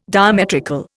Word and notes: diametrical: Indian

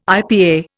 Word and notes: IPA: Indian

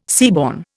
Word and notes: seaborne: Indian

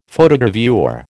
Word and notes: Photogravure: American English

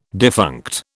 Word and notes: defunct: British English

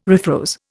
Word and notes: refroze: Indian

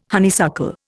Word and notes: honeysuckle: Indian

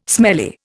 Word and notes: smelly: Indian

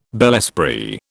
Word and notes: bel esprit: British English

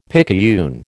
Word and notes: picayune: American English

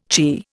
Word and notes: chirr: Indian

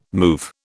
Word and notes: move: British English

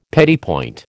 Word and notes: petit point: American English

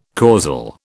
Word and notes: causal: British English

